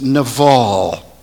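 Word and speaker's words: naval (נָבָל): Now, the word fool in Hebrew is pronounced naval.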